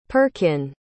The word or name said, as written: perkin